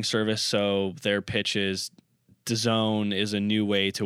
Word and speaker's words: DAZN: That's how they're pronouncing it. But if you read it in print, it's just capital D, all capitals. So it's capital DAZN. So they're, and then they're pronouncing it DAZN.